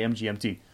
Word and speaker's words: MGMT: The decisive smash winner for last week's episode was Electric Feel by MGMT, which apparently is pronounced management, but if you want to be called management, just you got to name your band management. I'm going to say MGMT.